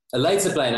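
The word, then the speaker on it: Leidseplein: And we went to some underground rave, like right outside of the red light district off of like, fuck, like not Liedenspleen, or is that how you say it? – the Waterloo Plain? – Is that the how you say it? Liedenspleen? – Liedenspleen, there it is.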